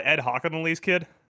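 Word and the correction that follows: Hochuli: How do you say it? – It's Ed Hockley. – I threw an extra syllable in there. You're right. – You threw an N in there.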